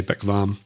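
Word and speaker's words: bekväm: It's a, in German, it's an umlaut, the two little dots over the A. So it's a long A, Beckvam.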